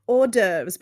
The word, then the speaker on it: hors d'oeuvres: Well, they did have horse duvers and other schnacks. – Okay. What? They had duvets made of horses? – No. Hors d'oeuvres, but it's spelt like that. I say horse duvers.